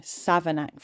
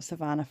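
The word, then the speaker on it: Savernake: So first of all, I just want to say that I said it wrong. Savanac Forest, not Savannah Forest.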